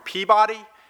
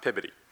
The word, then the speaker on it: Peabody: Are you from Peabody?" And then Congressman Mark quickly corrected me and said, "No, it's pronounced Pibody."